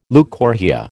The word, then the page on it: leucorrhoea: American English